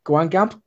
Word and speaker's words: Guingamp: Guingamp.